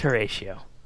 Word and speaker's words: Horatio: Horatio, or did you pronounce it wrong? – No, it is Horatio.